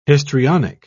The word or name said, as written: histrionic